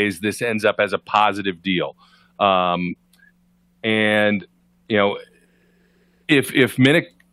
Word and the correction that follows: Minkah: how do you say his name? – Minkah. Come on, man. – Minkah. – It's like a mink coat.